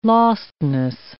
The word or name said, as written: lostness